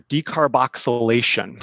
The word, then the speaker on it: decarboxylation: So I'm going to butcher this term, but decarboxylation? – No, you did good. – Okay. – Decarbing or decarboxylation.